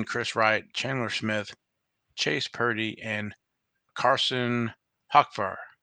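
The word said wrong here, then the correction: Hocevar: Hey, I got to pronounce here. Hocovar.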